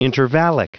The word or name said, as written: intervallic